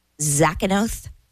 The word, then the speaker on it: Zakynthos: Zakynthos.